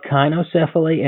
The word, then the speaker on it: cynocephaly: Cynocephaly. – No. – I've heard both kynocephaly and cynocephaly. Kyno or sino means dog, and cephaly means dog.